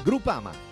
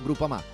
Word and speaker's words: Groupama: Groupama, ah no, si pronuncia Groupama, di Paolo Felicetti.